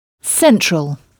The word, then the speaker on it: central: Et remarquez que là encore, on frappe la syllabe qui vient avant la terminaison en « all », donc « central ».